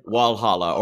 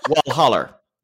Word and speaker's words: Walhalla: That's kind of like, I think Providencia is more like Walhalla, which is spelled like Valhalla, but it said Walhalla or often Walholler.